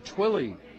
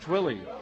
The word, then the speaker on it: Thuille: composer named Twilly. How's that for a name? Twilly. You'd never guess how it's spelled. T-H-U-I-L-L-E. – It's the hockey spelling, yes. – Is it? Okay. – Yeah, Twilly.